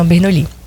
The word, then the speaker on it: Bernoulli: É Bernoulli? Como é que fala? – Exatamente, é o sistema Bernoulli.